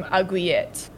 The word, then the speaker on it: aiguillette: In English, it's aglet. – Aglet. – In French, it was it stems from aiguillette. – Aiguillette. – They gotta make it all fancy. – Aiguillette.